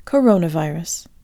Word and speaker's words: coronavirus: Coronavirus is a compound word made of the words corona and virus, and when we say it in English, each of these roots contains a stressed syllable. Coronavirus. The most authoritative Russian dictionary, authored by Russian linguist and scholar Andrei Anatolievich Zeliznyak, advises a double stress like in English,